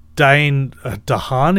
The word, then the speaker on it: Dane DeHaan: And I think the actor Dane DeHaan, is that how you pronounce it? – Yeah, from Chronicle.